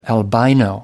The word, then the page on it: albino: American English